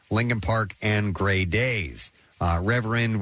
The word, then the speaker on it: RVRND: And he's using a moniker, which is pronounced Reverend. R-V-R-N-D is how that's spelled, all capital letters.